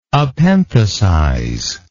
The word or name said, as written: epenthesize